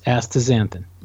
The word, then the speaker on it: astaxanthin: Astaxanthin? Is that how it's pronounced? – Yes, astaxanthin.